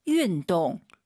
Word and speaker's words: 运动: 자, 운동 발음할 때 여러분 윈동 입을 가로로 버려서 윈동 윈동 다 알아들어요. 예쁜 발음이 아니에요. 다시 들어보세요. – 运动。 – 윈동 약간 입술을 동그랗게 말아보세요. 윈동 그게 더 예쁜 발음이에요. 원래 운동이 힘들잖아요. 쉽게 발음하시면 안 돼요.